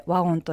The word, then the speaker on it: WOWN: お話をお伺いしますのは株式会社ワオン代表取締役の藤岡拓美さん、そしてウェブデザイナーの花輪恵里さんです。 2日にわたりどうぞよろしくお願いいたします。 – よろしくお願いします。よろしくお願いします。 – それでは初めに WOWN と書いてワオンと読む会社でいらっしゃいます株式会社ワオンがどんな会社なのか、事業の概要について藤岡さん教えてください。